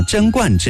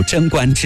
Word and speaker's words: 贞观: 唐太宗李世民在位期间，开创了贞观之治，也有人把那个字音读成是观啊，贞观之治。我们在这里不妨就读贞观之治。